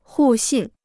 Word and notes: 互信: hù xìn

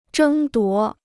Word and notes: 争夺: zhēng duó

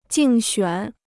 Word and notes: 竞选: jìng xuǎn

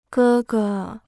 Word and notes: 哥哥: gē ge

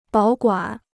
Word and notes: 保管: bǎo guǎn

